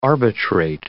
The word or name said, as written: arbitrate